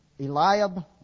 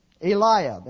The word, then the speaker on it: Eliab: Now somebody give me the right pronunciation of that name. Who? Eliab? Okay. Eliab.